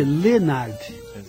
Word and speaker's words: Leonard: Aliás, o Leonard se escreve Leonard, mas Exatamente. Se lê Leonard.